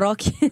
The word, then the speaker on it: rock: De rock. – Rock. Puis ouais, je pense que je vais le prononcer comme on le prononce en Amérique latine. Rock.